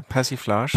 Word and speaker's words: Persiflage: Passiflage. Ja, äh, Persiflage. Persiflage, genau.